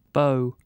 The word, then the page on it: beau: British English